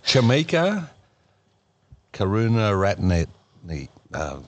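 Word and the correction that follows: Karunaratne: No, say it again. – Karuna Ratne. – Okay, that's better.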